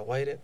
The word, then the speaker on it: Wired: Hoy va a sonar The Wired CD, que es un CD que sacó la revista Wired. No sé si la pronuncio bien o mal, pero... – Wired. – Bien. W-I-R-E-D. – Mejor.